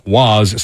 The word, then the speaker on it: Roy: No relation to Patrick Roy, which I did look up because I was curious, uh, and that is how you pronounce his name.